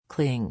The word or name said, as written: cling